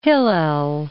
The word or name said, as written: Hillel